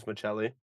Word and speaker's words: Maccelli: I think double C for Italians, Maccelli.